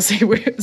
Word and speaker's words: Zellweger: Now, just for the record, I do not find Renelle Zellweger. So I can't even say her name right now. – Zellweger. – Yes, thank you.